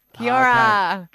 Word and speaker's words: Kia ora: Kia ora. – Oh, okay. Say it. – Kia ora. – Did she say it right? – Yeah, that's it. – You got to roll the R's.